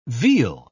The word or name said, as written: veal